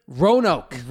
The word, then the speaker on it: Roanoke: Roanoke.